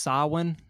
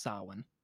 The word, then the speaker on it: Samhain: So again, it's pronounced Samhain. I don't know how they get that from. – Yeah, I thought it looks like Samhain or something. – Yes, yeah, Samhain or something.